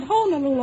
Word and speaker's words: Honolulu: They pronounce it Honolulu over there when I was there.